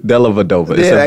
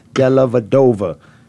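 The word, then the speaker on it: Dellavedova: That's like Della Dover. – Della Vadova. It's a V.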